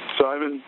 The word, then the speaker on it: Simon: E-Z-R-A. – What's your last name? – Simon, S-I-M-O-N, with a hyphen and Daniels.